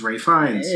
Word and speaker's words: Ralph Fiennes: Even though his first name is spelled Ralph, and I want to say Ralph, but it's Ralph Fiennes.